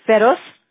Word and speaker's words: Ferroz: F-E-R-R-O-Z. – Okay, Feroz?